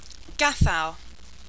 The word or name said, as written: gathow